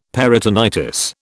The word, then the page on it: peritonitis: British English